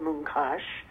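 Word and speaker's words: Munkács: And the place he was from in Hungary was called Mooncash, although it's spelled differently in Hungarian. – That's right. – I spelled it phonetically, because that's how I always heard it.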